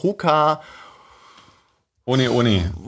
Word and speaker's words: Hoka One One: Es wird halt hier Hoka One One ausgesprochen, weil die hawaiianische, das ist ein hawaiianisches Sprichwort, Hoka Ohne Ohne.